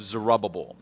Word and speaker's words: Zerubbabel: The governor of them was a man by the name of Zerubbabel. Now, how would you like to have a name like that? Two B's in the middle, both pronounced separately.